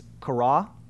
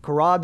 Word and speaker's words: Qarah: There's a Hebrew word called, I don't know if I'll say this right, but it's Qarah, Q-A-R-A-H. Yeah, we have it up there.